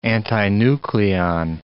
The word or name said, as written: antinucleon